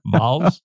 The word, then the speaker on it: Vols: It's as if we are, I'm going to a Vols game, Jimmy. – A what? – Vols. – What is that? Vols? – Was that how you say it? Vols? Oh, like volunteer.